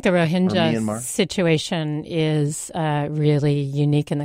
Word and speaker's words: Rohingya: And the Buddhists, the powerful Buddhists, the more powerful ones, are saying to the Rohingya, is that how you say it? – That's Rohingya, yeah. – Rohingya Muslims, don't belong there, should be gone, shouldn't be killing animals and assault their senses and sensibilities.